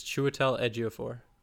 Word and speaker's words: Chiwetel Ejiofor: Anyway, my pick, and I'm gonna butcher this name, Chiwetel Ejiofor. – I think it's Chiwetel Ejiofor. – Okay, there we go. There it is.